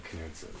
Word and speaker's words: Knutson: It's Knutson. The K is silent. – Right, but my argument to that is like it's whatever the hell I want it to be. Like my ancestors said Knutson.